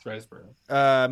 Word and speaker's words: Riseborough: I don't know how to say that one. Riseborough. – I think it's you said it right. – It's Riseborough.